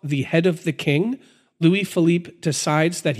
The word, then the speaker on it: Louis Philippe: Now, Louis Philippe, I'm probably saying this wrong. – Louis Philippe, thank you. – Yeah. – You know your French better than me.